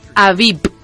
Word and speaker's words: AVIPPP: Bueno, ha sido aquí en España y se llama, a ver si lo digo bien, Avip, pero Avip se deletrea A-V-I-P-P-P. ¿vale? O sea, con tres P's al final.